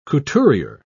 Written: couturier

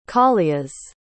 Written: callias

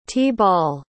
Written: t-ball

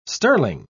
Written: sterling

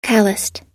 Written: callused

